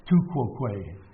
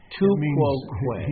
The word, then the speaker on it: tu quoque: Tu quoque. – Tu quoque.